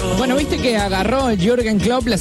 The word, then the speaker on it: Jürgen Klopp: Jürgen Klopp. – Jürgen Klopp. – Dígalo de nuevo. – Jürgen Klopp.